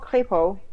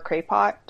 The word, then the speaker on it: Crépo: It's called Crépo, which is it's a mix between creativité and potential. So you could pronounce it like Crépo or Crépot, doesn't really matter.